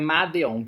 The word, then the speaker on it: Madeon: Então, primeiramente, né, produtores Medion, não sei se é assim pronuncia, e o Blood Pop. Medion trabalhou, é, é Madeon, tá, pra, a, é, a forma de escrever, trabalhou com Coldplay, com Ellie Goulding, também tá como compositor, junto com Justin Tranter, a própria Lady Gaga e o Blood Pop.